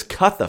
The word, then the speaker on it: Cutha: with C being a CH sound, we also have this Cutha fellow in Wessex, and we have Creota in Mercia. And there the C is pronounced with a hard C rather than a CH.